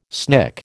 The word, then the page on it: snick: American English